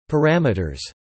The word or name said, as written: parameters